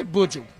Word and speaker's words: Buju: I say Buju.